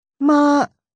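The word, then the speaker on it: Ma (妈): Flat tone. – Ma. Ma. – Means mother. Rising tone. – Ma.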